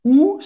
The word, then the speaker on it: où: Où sont les toilettes? Onde, em, em francês, é où, com acento, mesma pronunciado la letra U em português,